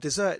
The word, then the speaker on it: desert: Dessert, it comes from deserving. So even though it's spelt like desert, he's not saying a desert is like a beggar. He's saying dessert, deservingness, the kind of what we expect to come about.